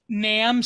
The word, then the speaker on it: Nam: Nam, so NAM, like Vietnam, uh-huh.